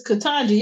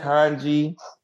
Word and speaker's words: Ketanji: So, to transition to more of a political topic, how do y'all feel about how to say the young lady's name? – It's Ketanji. Y'all gonna say this black woman name right? – Ketanji Brown Jackson.